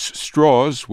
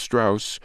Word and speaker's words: Strauss: The first Senate rejection I covered was in 1959 when Louis Strauss, which was the way he pronounced Strauss,